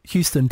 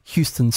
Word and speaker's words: Houston: One is pronounced Houston, Texas, and the other is called Houston Street.